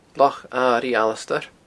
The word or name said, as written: Loch Airigh Alasdair